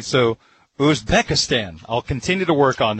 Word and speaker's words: Uzbekistan: Colombia beat Uzbekistan by a score of 3-1. Is that close? – You nailed it.